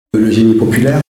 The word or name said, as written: genie populaire